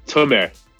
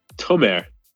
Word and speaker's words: Tomer: How do you even say your name? Tamor, is it? – Tom, Tomer. – Huh? – Tomer. T O M E R. Tomer.